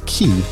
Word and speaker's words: Cay: You know, I was pronouncing that as Coco K because it's spelled C-A-Y. And that, to me, that spells K. But I guess the way they say it is Key. And so let's say it like the natives, Coco Key.